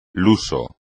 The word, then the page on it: luso: Spanish